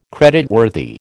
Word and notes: creditworthy: American English

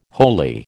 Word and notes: holy: American English

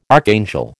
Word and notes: archangel: American English

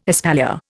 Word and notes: espalier: Indian

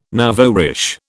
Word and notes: nouveau riche: British English

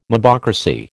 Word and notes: mobocracy: American English

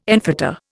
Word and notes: enfetter: Indian

